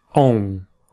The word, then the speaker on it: bom: In terms of pronunciation, it's worth mentioning here the sound on in bom. This is a nasal sound. A very common mistake is for students to pronounce that M at the end and say om. Try and avoid that. The M is silent. You need to nasalize the vowel before. So you need to nasalize the O. Om.